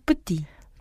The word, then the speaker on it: petits: Ils sont petits. – 저 s가 붙었지만 발음은 하지 않아요.